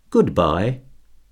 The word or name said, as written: goodbye